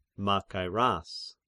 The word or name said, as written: Machairas